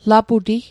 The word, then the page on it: laputi: Mi'gmaq